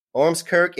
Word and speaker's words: Ormskirk: Today, we are talking to Anthony Grant um, of Edge Hill University in, did I say this right, Ormskirk? Yes. Ormskirk in the in in the UK.